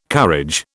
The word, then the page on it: courage: British English